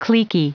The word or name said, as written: cliquy